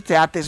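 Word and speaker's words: Theate: quien sería el reemplazante del belga Arthur Tieti. ¿Te pronuncio así? – Sí, Arthur Teate. – ¿Teate? – Sí.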